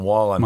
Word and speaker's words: Wallen: In July, Morgan Wallen, did I say his name right? I always say Whalen, yep. Wallen.